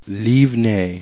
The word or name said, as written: livnei